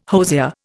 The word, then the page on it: hosier: Indian